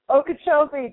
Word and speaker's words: Okeechobee: Lake Okeechobee. I hope I'm saying it right. – Okeechobee, that is correct.